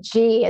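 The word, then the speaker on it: Ji: David G, it's J-I, and it's the Deep Healing Meditation.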